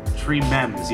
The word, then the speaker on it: Tremens: Is that how you say it? Tremens, yeah. Delirium Tremens.